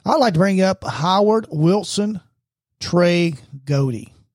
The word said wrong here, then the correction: Gowdy: Did I say it right? – Gowdy. – That's what I said. Gowdy.